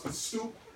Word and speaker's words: The Stoop: Facebook, you like the page, it's The Stoop, T-H-E-S-T-U-P, just like that on the bucket right there. It's pronounced The Stoop, not The Stup.